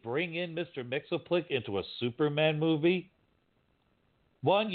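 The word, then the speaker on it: Mxyzptlk: Mitzelplick, however you're pronouncing it, was on Supergirl a couple weeks ago, and they pronounced every single letter in his name. – I know. I saw that one. It was like, Mix's Pitlick. I was like, what? – What? I was like, huh? Like, you don't pronounce every letter?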